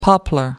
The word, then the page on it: poplar: American English